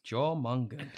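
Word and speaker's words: Jörmungand: Is that how you pronounce it? – Jormungand.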